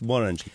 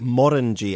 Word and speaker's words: Morangie: And it's pronounced Morangie, correct? – Morangie, as in rhymes with the fruit orange.